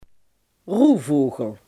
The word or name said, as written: nachtvlinder